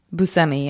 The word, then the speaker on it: Buscemi: Is that how you pronounce it? – Buscemi, yeah. Everyone always says Buscemi.